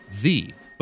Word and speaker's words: ye: Now old store names in England are preceded by ye, which is meant to be pronounced the, but rarely is.